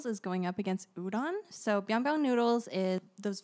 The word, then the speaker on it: udon: Also, pronunciation, udon.